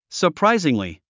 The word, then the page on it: surprisingly: sə r práiziŋli; サプライジングリィ